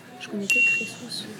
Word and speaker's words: Crésus: Je dirais Crésus.